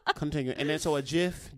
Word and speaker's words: GIF: If you call it a GIF, that's fine. But I just want to let you know that the actual pronunciation is GIF.